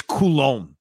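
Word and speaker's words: Coulombe: Tanny Kume, is that how you pronounce his name? The former Oriole reliever, Minnesota Twin reliever. I think it's Coulomb.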